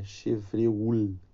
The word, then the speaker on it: Chevreul: Chevreux, Chevreux, é, Chevreul, que se escreve, explicou a natureza das gorduras.